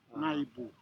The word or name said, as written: naybu